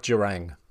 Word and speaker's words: Jurang: I like Jurang. It's got a ring to it that I like. – Rhymes with Kerrang and Terang.